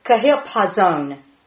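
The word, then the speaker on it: Kahilpazon (חפזון): KHIP-PAW-ZONE with the exclamation mark on the ZONE. The emphasis is on that part of the syllable. Kahilpazon.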